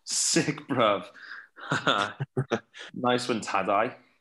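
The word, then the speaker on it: Tadej: Tadai. I learnt that. – Oh, yeah? Tadai. – Okay. He came on the GTCC podcast, my other podcast, Garen Thomas Cycling Club. And, yeah, that's how you say it anyway.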